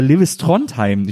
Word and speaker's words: Lewis Trondheim: Ich weiß, spricht man den so aus? – Ja, kann man. Ja. Also das ist ja sowieso ein Künstlername, der international funktioniert. Man kann Lewis sagen, kann Lewis sagen. Ja. Trondheim.